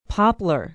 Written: Poplar

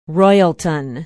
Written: Royalton

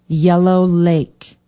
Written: yellow lake

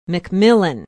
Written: mcmillan

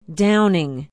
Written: downing

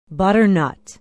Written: butternut

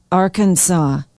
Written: arkansaw

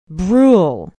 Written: brule

